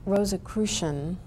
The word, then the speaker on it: Rosicrucian: Well, an old Rosicrucian, did I say it right? – Yes.